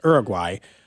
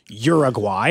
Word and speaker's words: Uruguay: I had a Spanish teacher who made us pronounce it that way for 4 straight years in high school, and I've never been able to go back. You know, some things are just ingrained in you. Like, I mean, there's just no way I'm ever going to pronounce it any way besides that way for probably, I'm not going to say Uruguay. I'm going to Uruguay. That's how they pronounce it.